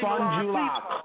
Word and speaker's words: Fond du Lac: Fond du Lac.